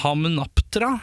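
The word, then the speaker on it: Hamunaptra: Hamnaptra. Jeg tror det var riktig. – Det er veldig 100 korrekt uttalelse. – Tusen takk. Hammenaptra!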